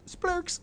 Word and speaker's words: Splurch: it's spelled S P L U R C H podcast. Splurks.